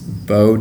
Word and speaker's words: Beau: So my wildlife, only Instagram page is Beau Day Photography.